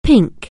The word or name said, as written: pink